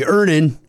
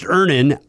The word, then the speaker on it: Earnin: I'm going to pronounce this properly. Earnin. – Earnin. – E-A-R-N-I-N is pronounced Ernin. I don't know how else you would pronounce it, but they really want it clear that that's how to pronounce it.